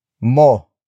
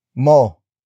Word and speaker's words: mo: The next word is mo. Mo. Mo. Mo is spelled as M O.